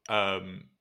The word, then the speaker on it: Subaru: So, Subaru, I guess, is probably my Australian pronunciation. I think the Japanese pronunciation is Subaru.